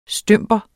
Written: stymper

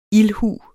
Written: ildhu

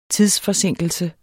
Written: tidsforsinkelse

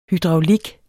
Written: hydraulik

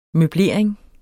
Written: møblering

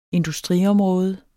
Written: industriområde

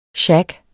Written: SHAGGEN

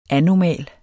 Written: anomal

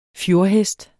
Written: fjordhest

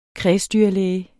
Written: kredsdyrlæge

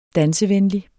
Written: dansevenlig

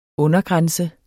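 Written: undergrænse

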